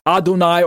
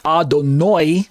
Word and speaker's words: Adonai: Strong's number 136 in the Strong's Concordance for Adonai is translated Adonai, or sometimes it's pronounced Adonoi. If you ever meet an Orthodox Jew, instead of saying Adonai, sometimes you'll hear them say Adonoi. Almost sounds like Adonoi, N-O-Y, instead of N-A-I, or something to that effect.